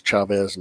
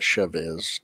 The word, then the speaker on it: Chavez: It is Chavez, not Chavez. Chavez.